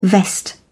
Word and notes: vest: British English

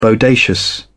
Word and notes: bodacious: British English

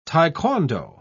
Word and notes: taekwondo: British English

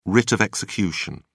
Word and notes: writ of execution: British English

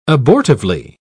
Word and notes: abortively: British English

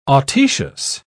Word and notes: autoecious: British English

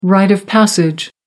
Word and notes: rite of passage: American English

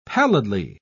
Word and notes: pallidly: British English

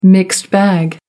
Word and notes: mixed bag: American English

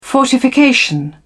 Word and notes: fortification: British English

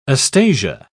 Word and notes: astasia: British English

